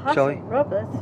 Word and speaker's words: Paso Robles: Paso Robles. By the way, did I say that right? – Paso Robles.